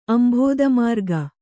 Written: ಅಂಭೋದಮಾರ್ಗ

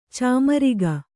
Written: ಚಮ್ಮರಿಗೆ

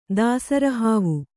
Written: ದಾಸರಿಹಾವು

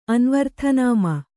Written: ಅನ್ವರ್ಥನಾಮ

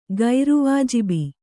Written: ಗೈರವಾಜಬಿ

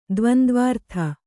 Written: ದ್ವಂದ್ವಾರ್ಥ

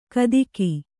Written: ಕದಿಕಿ

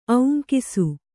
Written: ಔಂಕಿಸು

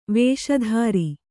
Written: ವೇಷಧಾರಿ

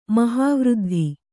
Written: ಮಹಾವೃದ್ಧಿ

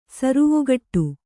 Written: ಸರುವುಗಟ್ಟು

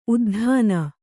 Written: ಉದ್ದಾನ

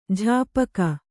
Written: ಜಾಪಕ

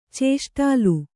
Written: ಚೇಷ್ಟಾಲು

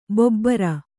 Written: ಭೂಭಾರ

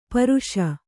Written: ಪರಾಶಿ

